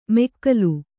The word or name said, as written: ಮೆಕ್ಕಲು